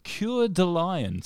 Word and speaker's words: Coeur de Lion: Which King of England was known as Coeur de Lion? I don't know how to pronounce that. C-O-E-U-R. Coeur? Yeah, just really more of a list of vows. Coeur de Lion.